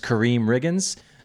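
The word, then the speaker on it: Karriem Riggins: So an artist is Kareem Riggins. Song choice is J Dilla, the greatest. And the drummer is Kareem Riggins.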